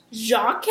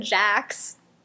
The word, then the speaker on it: Jacques: Jacques? – Jacques. – Jacques. – Jacques. Jacques, okay. – Jacques. – I didn't take French, remember this? – Jacques! – It's better than Jax.